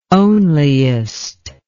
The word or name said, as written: onliest